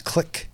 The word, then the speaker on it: Clough: Same spelling, but it's pronounced click.